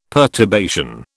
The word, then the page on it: perturbation: British English